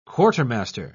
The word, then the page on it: quartermaster: British English